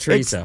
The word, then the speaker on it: chorizo: Chorizo? – Yeah. – Did I say it right? – That's perfect. Chorizo.